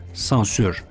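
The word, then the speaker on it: censure: Modern Fransızca'da sözcük hala aslına yakın bir şekilde sensure yazıldığı halde sansür diye okunuyor.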